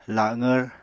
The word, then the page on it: laqvr: apatani